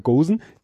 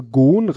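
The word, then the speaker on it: Ghosn: Aussprache von, ich sag jetzt wieder Gosen, ich habe Gon, reimt sich mit, also Gon, reimt sich mit Bonn, schreibt er extra gehört, hab den aber auch schon wie geschrieben ausgesprochen.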